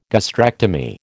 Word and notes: gastrectomy: American English

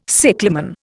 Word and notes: cyclamen: Indian

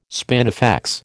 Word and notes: spinifex: American English

